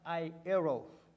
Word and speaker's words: eros: One, the first one that, we, that we're going to be talking about is a love is a, uh, found in the Greek word eros, and it's pronounced I eros.